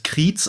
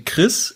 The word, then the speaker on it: Chriez: Und zwar C-H-R-I-E-Z. Das ist so sein Markenzeichen. Jeder Normaler würde das Kriez aussprechen, aber er hat es eingedeutscht. Er sagt Chris, er kommt halt aus dem Süden, aus Deutschland, da gibt es ein bisschen komische Namen.